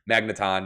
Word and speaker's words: Magneton: I always thought Magnemite and Magnetron were really cool. Magneton, not Magnetron.